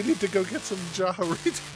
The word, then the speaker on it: Jarritos: He was frustrated with our pronunciation of... – Jarritos. Yeah. – He wants us to know that it is Jarritos. – Jarritos.